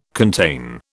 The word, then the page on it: contain: British English